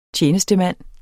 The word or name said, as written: TJENESTEMANDEN